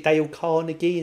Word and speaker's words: Dale Carnegie: Is it Dale Carnegie? Is that how you say it? – Yes.